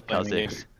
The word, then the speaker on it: Kha'Zix: You know, we all have our regional dialects, but it's just funny because I hear Kazix and I'm thinking like Ziggs, but Kazix is I think how most people say it, Kazix. And so it was just funny when you were saying Kazix It's definitely Kazix.